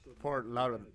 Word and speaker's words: Fort Lauderdale: Fort Lauderdale. – How do you, say it now correctly? – Fort Lauderdale.